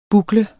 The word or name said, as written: bukle